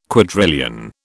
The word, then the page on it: quadrillion: British English